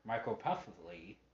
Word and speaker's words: microcephaly: And, you know, I mean, when I first saw her on American Horror Story on the seasons, because her character has a micropuffly, that's pronounced M-I-C. R-O-C-E-P-H-A-L-Y,